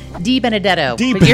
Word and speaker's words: DiBenedetto: DiBenedetto.